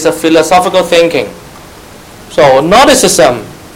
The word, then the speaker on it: Gnosticism: Gnosticism. You don't pronounce the G. Gnosticism. Gnostics. This word means knowledge. So Gnostics mean knowledge. Gnosticism is the noun of the thinking.